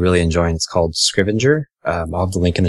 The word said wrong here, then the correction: Scrivener: And Scrivener. – Yes, that thing. – Scrivener.